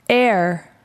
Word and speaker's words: heir: Heir, heir. – 상속자. Heir에서 heir라는 것은 달라붙다라는 뜻입니다. 그래서 달라붙은 피붙이란 뜻에서 상속자입니다. 그런데 조심해야 될 것은 h는 읽지 않습니다. Heir라고 읽어주십시오.